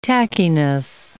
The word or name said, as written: tackiness